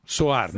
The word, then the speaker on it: Sohar: Oman é um país Como é que se escreve Sohar? – Sohar é S-O-H-A-R. – Soar, né? Soar. – Isso.